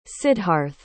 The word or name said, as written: sidharth